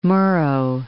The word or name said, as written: Murrow